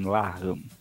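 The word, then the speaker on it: Larghi: O Gostinho falou que é um nome que é pronunciado assim, Larran.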